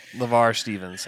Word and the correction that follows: Lamar Stevens: Uh, Lamar Stevens. Oh my gosh, I've said his name wrong 500 times.